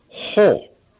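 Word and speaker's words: ho (ὁ): Now, in the Greek, this is an omega with a breathing sign and is to be pronounced ho. Okay? Ho.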